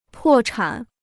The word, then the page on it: 破产: pò chǎn